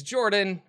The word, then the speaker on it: Jorphdan: My name is Jorphdan with a silent PH in the middle, and I'm joined always by my wonderful co-host, Sir Lucien, over at Sir Lucien Gaming.